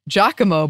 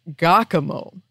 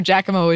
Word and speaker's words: Giacomo: With regards to mispronunciation, I can't remember the podcast, but I believe Annie mentioned Giacomo, but pronounced it Giacomo.